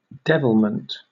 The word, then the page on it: devilment: British English